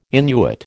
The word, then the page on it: Innuit: American English